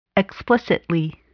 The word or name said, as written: explicitly